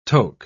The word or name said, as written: toke